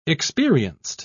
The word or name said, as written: experienced